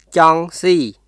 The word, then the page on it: ចង់ស៊ី: チョン・シー; cɔŋ siː